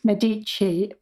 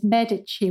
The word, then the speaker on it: Medici: So to start us off then, how do you actually pronounce Medici? – English speakers will often say Medici, but the Italian pronunciation is Medici with the stress on the first syllable.